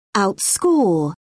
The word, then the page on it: outscore: British English